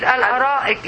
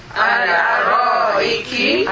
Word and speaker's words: الأرائك: الأرائك. – I want you to pronounce it correctly. ال. – ال. – أ. – أ. – رائك. – الأرائك. الأرائك.